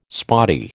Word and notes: spotty: American English

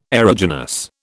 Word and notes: aeruginous: British English

